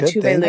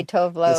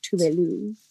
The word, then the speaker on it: Tove Lo: Is that how you pronounce it? – Yeah, Tuvalu. – Oh, yeah, I always say Tovlo. – So do I. She kind of like goes by both. I think now they're trying to get people to pronounce it. That's like the Swedish like pronunciation is like Tuvalu.